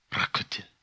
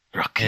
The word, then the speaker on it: Rakuten: How do you pronounce this? Rakuten? – Rakuten. – Okay. – I think it's Japanese. You got to put a little bit of guttural in there. – Rakuten.